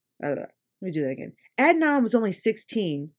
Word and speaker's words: Adnan: So Adnan Syed was convicted of how do you say his name? I think it's Adnan. Adnan. Adnan Syed was convicted of strangling. Adnan, right? Yes.